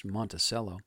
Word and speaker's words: Monticello: He was a Seattle delegate to the Monticello Convention. I've also heard that pronounced Monticello, a semi-formal congress of northern citizens who sought to divorce their part of the territory from that south of the river.